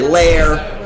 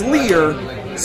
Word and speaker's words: Lehr: No, but with Lear, my last name Lear, L-E-H-R, it's the German spelling, but a Ellis Island pronunciation, right? So people want to say Lair, but it's Lear.